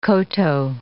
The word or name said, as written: koto